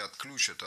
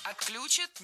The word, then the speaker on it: отключат: Отключат? Неверно. Правильно, отключат. Что интересно, в глаголах с корнем ключ. Ударение на этот самый корень никогда не падает.